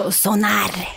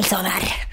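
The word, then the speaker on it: Sónar: Du uttalar det som norskt bara. Mm. – Den kan... Sonar. Mm. El festival Sonar.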